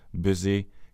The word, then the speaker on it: busy: Veldig mange nordmenn sliter med denne lyden, og bruker gjennomgående den ustemte S-en i ord som this, is, busy, cousin og music er alle ord som uttales med en stemt S, altså these, is, busy, cousin og music.